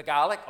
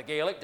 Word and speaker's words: Gaelic: And yet, although I didn't understand the Gaelic, or the Gaelic, depending how you pronounce it,